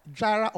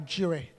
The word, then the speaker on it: Jireh: Jireh or Jireh. Depends on how you pronounce. Some people say Jehovah Jireh. Some people say Jehovah Jireh, but it's all correct.